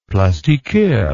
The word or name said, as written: plastiqueur